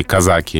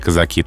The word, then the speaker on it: казаки: 1812 год, наполеоновские воины, русские казаки или казаки, там двойное ударение, по Парижу, в забегаловки кричат «быстро, быстро», и французы это слово переначали и сделали «бистро».